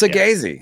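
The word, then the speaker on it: Saggese: Thomas Segese. Yeah. Is that how you say his name? – Yeah.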